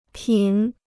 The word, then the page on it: 品: pǐn